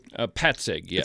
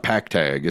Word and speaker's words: Petzig: And it looks like our good friends, Andy Petzig. Am I saying that right? – Petzig, yes. – It's not Pactag. It's Petzig.